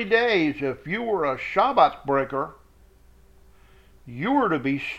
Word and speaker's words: Shabbat: That's spelled Shabbath. The T-H is sort of a lisping T. Shabbat. Not Shabbath. That's not how it's pronounced. It's Shabbat with a th at the end.